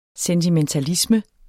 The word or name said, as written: sentimentalisme